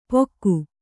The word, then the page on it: ಪೊಕ್ಕು: Kannada